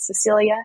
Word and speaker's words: Cecilia: Name is spelled Cecilia, C-E-C-I-L-I-A, Langree, L-A-N-G-R-E-E, although I'm sure it'll be in the notes for this show as well.